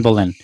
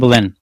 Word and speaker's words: Boleyn: is her connection with the Boylan. I forgot her name. – Anne Boleyn. – Yeah. I forgot how to pronounce it. Anne Boleyn.